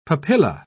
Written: papilla

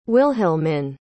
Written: wilhelmine

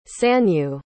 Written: sanyu

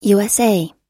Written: usa

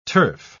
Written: turf

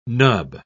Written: nub

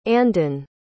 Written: andon

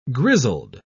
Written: grizzled

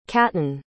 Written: caton

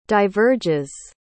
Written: diverges

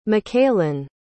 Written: makaylyn

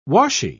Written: washy

